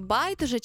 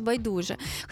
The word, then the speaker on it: байдуже: З тобою в ефірі я, і часто чую суперечки, як правильно сказати «байдуже» чи «байдуже». Хтось каже з наголосом на перший склад, хтось на другий. Так от, офіційно знімаємо весь стрес, тому що мовознавці дають повну свободу. Правильно обидва варіанти.